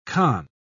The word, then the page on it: khan: British English